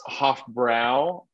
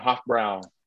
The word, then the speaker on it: Hofbräu: it's Hofbräu. So just want a correct pronunciation. Hofbräu.